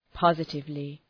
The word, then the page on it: positively: ‘pɒzıtıvlı